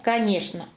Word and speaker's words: конечно: Мы пишем согласно «ч», а говорим «скворечник». Что, конечно, а произносим «ш».